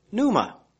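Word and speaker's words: Pneuma (πνεῦμα): and spirit, which is number 7307, and it's spelled R-U-A-C-H, Kodesh Ruach. In the New Testament, it's called Hagios Pneuma, H-A-G-I-O-S, or number, Greek word 40, Pneuma, and we know Pneuma, it's P-N-E-U-M-A, which is number 4151.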